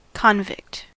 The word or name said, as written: convict